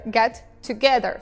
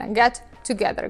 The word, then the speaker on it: get together: Никто не говорит вот так вот раздельно get together. Говорят вот вместе get together, как будто бы используя одну букву T.